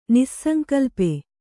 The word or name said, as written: ನಿಸ್ಸಂಕಲ್ಪೆ